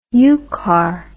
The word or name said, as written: yukar